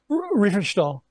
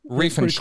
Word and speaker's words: Riefenstahl: But first, you had mentioned a story about a woman, and I don't even know if I'm pronouncing her name right. Lenny Riefenstahl? – Riefenstahl. – Riefenstahl. – Close enough.